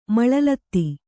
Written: ಮಳಲತ್ತಿ

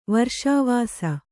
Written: ವರ್ಷಾವಾಸ

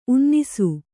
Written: ಉನ್ನಿಸು

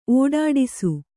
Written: ಒಡ್ಡೈಸು

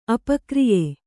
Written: ಅಪಕ್ರಿಯೆ

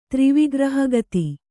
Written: ತ್ರಿವಿಗ್ರಹಗತಿ

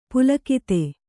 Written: ಪುಲಕಿತ

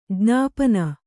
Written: ಜ್ಞಾಪನ

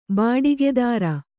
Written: ಬಾಡಿಗೆದಾರ

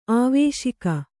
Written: ಆವೇಶಿಕೆ

